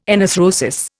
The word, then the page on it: enarthrosis: Indian